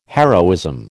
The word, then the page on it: heroism: American English